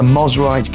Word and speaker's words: Mosrite: Is a, I'm hoping I'm saying this right, a Mosrite guitar. Does that sound right? – Sounds right to me.